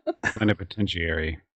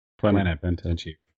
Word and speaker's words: plenipotentiary: Yeah. Phalaenopotentiary instrument. – Plenipotentiary. – Okay. – Plenipotentiary. – Let's say it again.